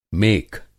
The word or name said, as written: मेख